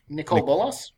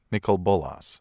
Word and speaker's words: Nicol Bolas: How do you pronounce that planeswalker? – Nicol Bolas. – Nicol Bolas.